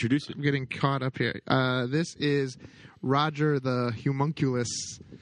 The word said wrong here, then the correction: homunculus: It's pronounced homunculus.